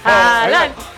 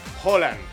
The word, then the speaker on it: Haaland: Es con O. – Y es rápido, es Erling Haaland. Así es su nombre. – Pero es con doble O. – No, es con A, con doble A. Es con doble A, pero él dice, o sea, haz de cuenta que normalmente cuando hay torneos se graban cómo es mi nombre.